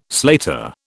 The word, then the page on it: slater: British English